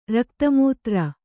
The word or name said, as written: ರಕ್ತಮೂತ್ರ